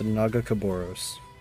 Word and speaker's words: Nagakabouros: It's pronounced Nagakabouros.